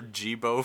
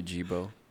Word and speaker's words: Geebo: Gabe or Jibo for short. – Gorbo. – I'm just gonna start saying that. Just call me Jibo. – But tell him to spell it G-E-E-B-O. – or just G-B-O.